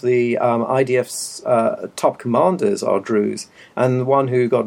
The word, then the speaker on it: Druze: And 6% is Diz. – Druze.